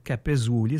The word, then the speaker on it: Capezzuoli: Capezzuoli, ça s'écrit C-A-P-E-Z-Z-U-O-L-I.